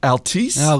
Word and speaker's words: Altice: Altice? – Okay, I'll go with that. – A-L-T-I-C-E. Yeah, uh-huh.